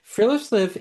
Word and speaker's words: friluftsliv: of friluftsliv.